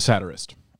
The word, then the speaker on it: satirist: You may become an edgelord, but you have to work to be a satiricist. – A satirist.